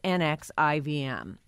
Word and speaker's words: NXIVM: Spelled NXIVM, but pronounced NXIVM.